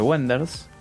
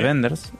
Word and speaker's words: Wenders: Después tenemos Hola, soy Silvia Lumato para la consigna París, Texas, de Wenders. – Sí. – De Wenders, en realidad.